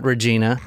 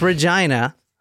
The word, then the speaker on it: Regina: The next stop was Regina, and that is how you say it, not Regina. It's Regina. I saw a video of someone saying Regina.